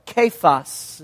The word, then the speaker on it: Cephas: And he says, Simon, son of John, we'll talk about that here in a minute, you shall be called Cephas, or Kephas is the way it's pronounced, which translated means Peter.